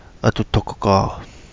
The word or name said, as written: Adu Dogo Gaa